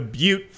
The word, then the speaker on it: Butte: But back in 2008, there was a fire called the Butte Fire, and it's spelled B-U-T-T-E. And, you know, we've all hit the air and not read scripts before, right? I'm not alone in that. So I called it the Butt Fire.